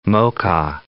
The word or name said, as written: Moca